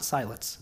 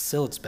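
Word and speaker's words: Siletz: Maybe you've been to Sillitz Bay here in Oregon. I worked on my pronunciation. It's not Sillitz, it's Sillitz Bay.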